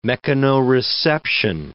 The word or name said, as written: mechanoreception